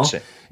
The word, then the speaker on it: Elche: Keine Ahnung, wie es auf Spanisch ausgesprochen wird. – Elche. Elche. Elche. Elche. Elche. Genau. Elche.